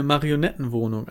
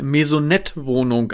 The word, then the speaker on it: Maisonettenwohnung: In eine Marionettenwohnung, eine Maisonettenwohnung, André. – Ja, ja, Maisonettenwohnung. Okay, ich habe das falsch ausgesprochen.